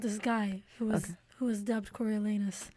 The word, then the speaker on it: Coriolanus: So, Coriolanus? Coriolanus? How do I say it? – Coriolanus.